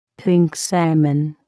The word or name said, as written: pink salmon